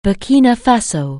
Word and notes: Burkina Faso: anglais

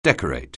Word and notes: decorate: anglais

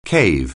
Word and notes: cave: anglais

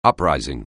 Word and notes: uprising: anglais

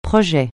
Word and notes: projet: francais

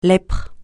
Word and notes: lèpre: francais

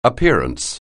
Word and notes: appearance: anglais

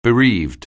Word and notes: bereave: anglais

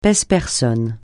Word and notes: pèse-personne: francais

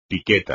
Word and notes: piqueta: espagnol

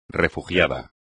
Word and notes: refugiado: espagnol

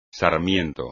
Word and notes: sarmiento: espagnol

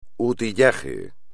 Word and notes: utillaje: espagnol

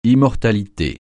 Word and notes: immortalité: francais

